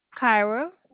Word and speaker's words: Kyra: Kyra. – Spell that for me, please. – K-Y-R-A.